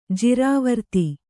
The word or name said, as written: ಜಿರಾವರ್ತಿ